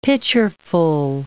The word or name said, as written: pitcherful